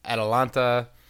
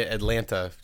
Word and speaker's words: Atalanta: like you got the Romas, Napoli's, um, Inter's coming up there, AC, um, Atalanta. Um, I feel like I always pronounce that wrong. Like I want to say Atlanta because it's our boys and girls uh, over at the Unrelegated podcast.